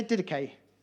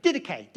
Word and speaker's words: Didache: All right, can everyone say Didache? Didache, Didache. That's how you pronounce it.